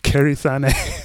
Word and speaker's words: Kairi Sane: Kairi Sane.